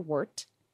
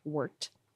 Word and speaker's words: wort: And that's your wort. Thank you again, Sheldon, for that pronunciation note. Wort. Like wort.